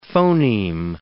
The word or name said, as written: phoneme